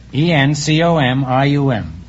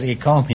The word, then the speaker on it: encomium: For some curious reason, I said ecomium instead of encomium.